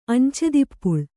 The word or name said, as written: ಅಂಚೆದಿಪ್ಪುಳ್